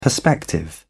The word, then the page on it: perspective: British English